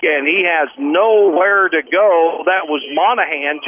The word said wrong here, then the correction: Monahan: James Monum is the pronunciation.